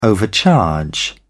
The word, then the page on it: overcharge: British English